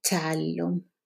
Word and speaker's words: التعلم: So make sure to pronounce this shadda after the definite article. it التعلم double tap.